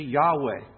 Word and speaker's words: Yahweh: We pronounce it generally Yahweh today. God gives to Moses his name.